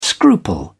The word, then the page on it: scruple: British English